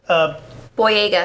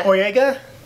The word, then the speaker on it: Boyega: It is John, and God help me on pronouncing this name. – Boyega. – Boyega? Thank you.